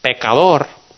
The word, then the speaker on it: pecador: And Spanish, pecador, is sinner. But if you just pronounce it just a little bit different, you can say pescador.